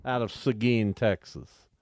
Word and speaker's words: Seguin: Seguin is how they pronounce it. S-E-G-U-I-N.